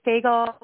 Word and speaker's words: Fagell: It is Fagell, rhymes with bagel.